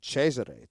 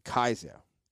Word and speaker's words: Kaiser: So that's why the Germans don't say Cesare, they say Kaiser.